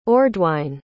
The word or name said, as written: ordwine